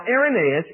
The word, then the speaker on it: Irenaeus: And his name is Irenaeus, and that word is spelled I-R-E-N-A-E-U-S. It's pronounced Irenaeus.